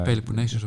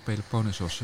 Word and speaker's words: Peloponnesos: Peloponnesus of Peloponnesos, waarbij de ene de correcte Nieuw-Griekse uitspraak is en de andere de correcte Oud-Griekse uitspraak.